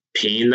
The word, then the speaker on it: Pijn: So and this band Pain that we were playing with, it's spelled P-I-J-N, like if somebody spelled pigeon incorrectly.